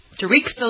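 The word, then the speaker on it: Tareq Salahi: Oh, T-A-R-E-Q. – Yeah. – Tarek? – Tarek Salahi.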